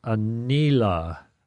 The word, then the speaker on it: Aniela: All right, this is from a 9-year-old, Aniela. I'm not sure I get her right, name right, but Aniela, if that's – I'm just pronouncing all the vowels like Spanish pronunciation, A-N-I-E-L-A.